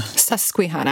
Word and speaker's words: Susquehanna: Susquehanna.